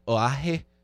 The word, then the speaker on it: Oahe: No, Energy Transfer Partners, they actually began work on Lake Oahe. That is the correct pronunciation.